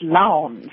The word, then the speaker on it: Lowndes: It's a strange name, and the E is silent, so you actually say it Lowndes.